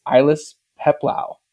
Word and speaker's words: Ailis Peplau: Our guest today is Ilis Peplau.